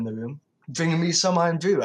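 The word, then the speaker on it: Irn-Bru: Okay, so obviously, Iron Brew. Okay. – In Scotland, there's a drink called Iron Brew. It's spelled IRNBRU.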